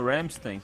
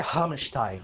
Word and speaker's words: Rammstein: A, a, pronúncia, é, a pronúncia é Hamstein. Hamstein. Hamstein. Ah. É assim que é.